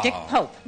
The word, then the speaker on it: Dick Pope: For achievement in cinematography, the nominees are Dick Poop. – Oh, Dick Pope.